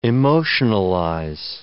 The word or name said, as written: emotionalize